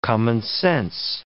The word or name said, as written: commonsense